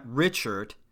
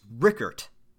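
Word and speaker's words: Richert: It's not Richard, it's Rickert. I said, boy, did I fuck that up.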